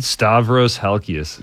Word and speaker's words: Stavros Halkias: Stavros Halkias.